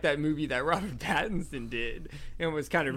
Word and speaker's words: Pattinson: There's a scene that, Robert Pattinson, I actually said it right this time, did in the Twilight movie that came out, like, a year before this.